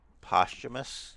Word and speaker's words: posthumous: They're probably working on some kind of posthumous documentary now. – Posthumous? – Yeah. – Is that really how it's pronounced? – Yeah.